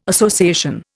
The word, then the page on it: association: Indian